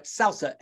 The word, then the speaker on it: SALSA: John also chairs, John, am I going to say this right? SALSA, S-A-L-S-A, was that correct? – That's right. Thank you.